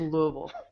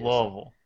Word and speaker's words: Louisville: We do not say that. It is Louisville. it's as if there were more syllables and letters involved.